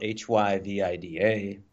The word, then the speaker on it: HyVIDA: So HyVIDA. So it rolls off. It's three syllables.